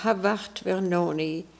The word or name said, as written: Havatvernoni